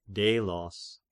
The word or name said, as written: Delos